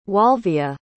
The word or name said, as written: walvia